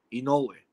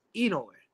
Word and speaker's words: Inoue: You know that's the proper pronunciation is like saying E and saying no way. So oh, Inoue. Really? Inoue. – Inoue. – Yeah, and the accent goes on the no, Inoue. – Right. – He does it on the E, Inoue.